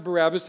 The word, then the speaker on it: Barabbas: All right, so we pronounce it Barabbas. That's not how it would have been pronounced in the ancient world.